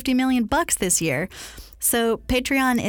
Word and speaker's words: Patreon: It's Patreon.